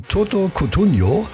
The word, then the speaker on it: Toto Cutugno: So, und am allerliebsten, schreibt die Corinna, hört sie von Toto Cutugno. Habe ich das richtig ausgesprochen? Das ist ja Italiener. Ja, das hast du fantastico ausgesprochen, aber es war Umberto Tozzi.